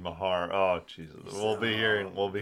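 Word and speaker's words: Meagher: By the way, learned that Rick Meager's name is not pronounced Rick Meager and is pronounced, I think, Rick Mahar.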